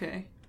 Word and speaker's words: Yasuke: I think Yasuke.